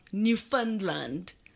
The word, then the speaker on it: Newfoundland: which I think is pronounced Newfoundland. – Like fund.